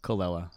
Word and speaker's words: Colella: Colella.